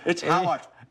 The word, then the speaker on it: Harwich: It's Harwich. – H-A-R-W-I-C-H, Harwich.